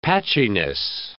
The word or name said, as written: patchiness